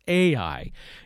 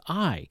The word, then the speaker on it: Ai: Now, you may have heard it pronounced Ai. It is spelled Ai, but it is actually, in Hebrew, the correct pronunciation is Ai.